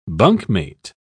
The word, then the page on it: bunkmate: British English